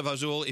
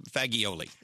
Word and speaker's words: fagioli: By the way, pasta fazool, if you're from the South, it's spelled fagioli. – Yes, it is. All right. Fagioli.